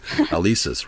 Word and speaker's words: Alesis: Yeah, I pronounced it wrong. I said Alexis. I don't know why I said that, but Alesis, right.